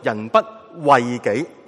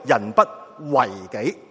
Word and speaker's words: 人不为己: 原来有个说法，就是话有个另外一个正确嘅读音，就唔系读人不为己，系读人不为己。为系咩意思呢？就唔系再讲咧为自己筹谋嗰个为啦，系讲到为己系解做咧关注设想，系嗰个生命品格情操嘅修为。人不为己系修为自己，完善自己嘅修养。